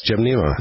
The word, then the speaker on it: Gymnema: There's Gymnema. Is that how you pronounce it? – Yes, Gymnema.